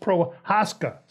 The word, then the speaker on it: Prochazka: And provided Prochazka has space, and I believe he wants his name pronounced Prohaska, so excuse me.